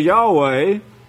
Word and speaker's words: Yahweh: It's pronounced in Hebrew. You could go to a Hebrew and speak Hebrew and say Yahweh. That's the way you pronounce it.